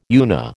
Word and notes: UNA: American English

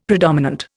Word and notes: predominant: Indian

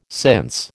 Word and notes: since: American English

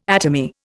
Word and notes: atomy: Indian

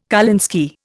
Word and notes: kolinsky: Indian

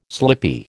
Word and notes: slippy: American English

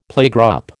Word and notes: playgroup: American English

